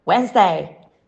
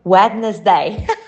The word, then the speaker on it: Wednesday: Right pronunciation, Wednesday. Common mispronunciation, Wednesday. The spelling of Wednesday makes it look longer than it sounds. In reality, the first D is silent and the middle syllable is omitted altogether. Huh, that's why.